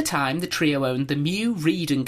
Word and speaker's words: Meux: Mew as in M-E-W, Mew. – No, as in M-E-U-X, but pronounced Mew.